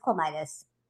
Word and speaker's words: Klimitas: It's Klimitas.